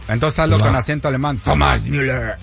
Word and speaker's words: Thomas Müller: Rápidamente, es Thomas Müller. Ah, lo pronuncié bien, discúlpame. Thomas Müller.